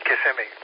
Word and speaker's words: Kissimmee: Now it's spelled like kiss me. Kissimmee.